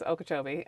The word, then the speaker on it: Okeechobee: You're like, Okeechobee. It's Okeechobee.